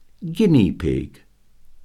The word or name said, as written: guinea pig